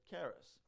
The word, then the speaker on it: charis: And in the way of introduction also there, the Greek transliteration of grace is kharece, and we pronounced it charis.